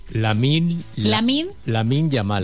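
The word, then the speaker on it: Lamine Yamal: ¿Qué pone? La mil llamal. Lamin Yamal. ¿Cómo se dice? – Lamin Yamal. – Yamal. – Bueno, pues... – Ah, es una L y una Y.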